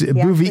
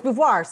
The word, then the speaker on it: Beauvoir: No, it's not Beauvoir. – It's Bouvier. He pronounces it. – Bouvier? – No, it's Beauvoir. Sorry. Not Bouvier. Sorry. No, you were right.